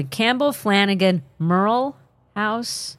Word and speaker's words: Campbell Flanagan Merle House: Now, I do want to ask you about the I don't know if I'm going to say this correctly. Campbell Flanagan Merle House? – Yes. Yeah, you said it correctly. – Because it's going to go Merle or Morel. I wasn't sure.